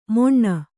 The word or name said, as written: ಮೊಣ್ಣ